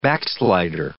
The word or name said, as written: backslider